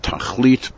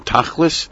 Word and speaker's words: Tachlis: It's sometimes pronounced Tachlit, but you have to say it in the Yiddishized version, Tachlis.